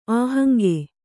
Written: ಅಹಂಗೆ

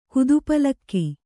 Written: ಕುದುಪಲಕ್ಕಿ

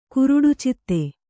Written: ಕುರುಡುಚಿತ್ತೆ

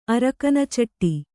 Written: ಅರಕನಚಟ್ಟಿ

